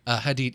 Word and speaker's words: Hadid: Hadid. – Yeah, it was right then.